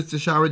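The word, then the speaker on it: Tishaura: I believe she pronounces it Tashara. – Yep. Okay. Congratulations to Tashara Jones in St. Louis for becoming the first black female mayor.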